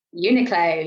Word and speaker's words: Uniqlo: Is it Uniqlo? – Uniqlo, yeah.